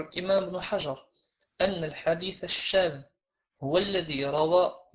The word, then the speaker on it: الشاذ: الحديث الشاذ، الشاذ مش الشاذ، طيب، الشاذ الذال تنطق هكذا ذ وليس ذ.